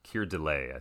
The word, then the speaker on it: Keir Dullea: Keir Dullea, I think is how you say his name.